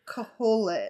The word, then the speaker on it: Kohelet: You know, I'll do my Google searching just to see if is it Colette or how do you say Koholet? – Koholet. – It's K O H E L E T, son of David.